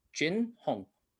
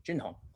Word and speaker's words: Jin Heng: Jin Hong. J-I-N-H-E-N-G. Jin Hong.